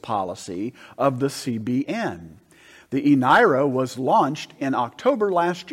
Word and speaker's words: eNaira: The eNaira. – eNaira,